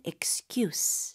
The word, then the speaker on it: excuse: 그리고 excuse가 아니죠, 쌤. – 맞아요. 이거는 명사라서 발음은 excuse. – 쓰처럼 끝내야 되겠네요.